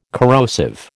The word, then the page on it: corrosive: American English